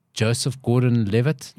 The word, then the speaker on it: Gordon-Levitt: Joseph Gordon-Levitt, I said it right.